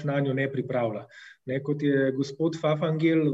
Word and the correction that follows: Fafangel: v, eh, oziroma Fafangel, mislim, da se pravilno izgovori,